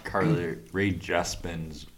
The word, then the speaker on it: Carly Rae Jepsen: Besides for the fact that Justin Bieber sucks and Carly Rae Jepsen's...